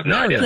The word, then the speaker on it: Mario: How does he say it in the movie? – Mario. – Mario. – Mario. – That's how you pronounce it. That's Mario. – The correct way.